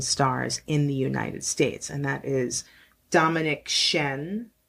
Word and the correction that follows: Dominique Crenn: I believe is how you say no Dominique Crenn.